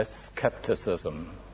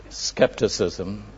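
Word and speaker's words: scepticism: First, by cynicism, and secondly, by skepticism. I think actually that's the American pronunciation, for the sake of our American friends who are here. But the English pronunciation is confusable with a disease. It's skepticism and cynicism.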